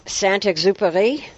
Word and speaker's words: Saint-Exupéry: Saint-Exupéry.